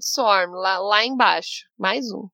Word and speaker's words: Swarm: É Swan. Eu não sei como pronuncia. também você consegue ajudar? – S-W-A-R-M. – Swarm.